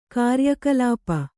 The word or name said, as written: ಕಾರ್ಯಕಲಾಪ